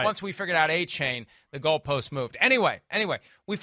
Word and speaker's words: Achane: There's a change in the pronunciation. There's a new pronunciation for Achane. Once we all figured out it's Achane, and we spent a lot of time in the studio on Sunday making sure everybody knew it was Achane.